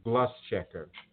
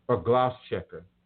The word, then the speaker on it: Gloucester: The first recorded slave revolt in the United States happened in Gloucester, Gloucester. I appreciate my mama and sometimes my sister for helping me with my pronunciation. Gloucester, Virginia.